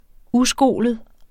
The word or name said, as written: uskolet